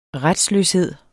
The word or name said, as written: retsløshed